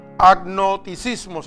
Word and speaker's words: agnosticismo: Infidelidad y agnoticismo se llama. Oiga bien, el que lo quiera escribir se escribe A-G-N-O-S. T-I-C-I-S-M-O.